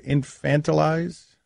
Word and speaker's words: infantilize: Is that how you pronounce the word? Infantilize? How do you pronounce the word? Turn these players into infants. I know, I'm looking at the wrong person. – What? Stugatz. – Infantilize. – Thank you.